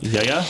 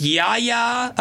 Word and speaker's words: Yahya: They cast, how do you pronounce his name? I don't want to mess this up. – Yaya? – Yeah, it's Yahya Abdul-Mateen II been cast as actually the lead role in The Matrix 4.